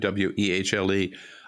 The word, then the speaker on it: Wehle: And Sabrina, I read Kim Wehle, I believe you pronounce her name, Wehle,